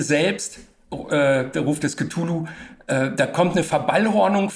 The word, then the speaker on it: Cthulhu: Und jetzt, nachdem du das eben auch schon äh, so ausgesprochen hast, also Cthulhu spricht man eben auch nicht mit TH, sondern.